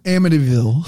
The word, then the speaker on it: Amityville: Amityville.